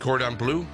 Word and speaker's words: Cordon Bleu: Chicken cordon bleu? – Yeah.